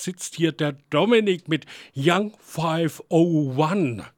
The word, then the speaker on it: Young 501: Spricht man das tatsächlich Englisch aus oder sagst du Young 501? – Nee, also erstmal hallo und ich sag mal herzlich willkommen zur Sendung. Spricht man tatsächlich so aus? Also ist richtig. Bin ich auch immer sehr froh, wenn Leute es richtig aussprechen.